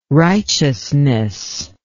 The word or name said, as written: righteousness